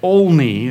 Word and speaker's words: Olney: Sorry, Olney is how you say it.